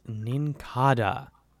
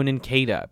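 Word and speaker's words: Nincada: Number 290 is Ninkada. No, Ninkada, because it's based on a cicada.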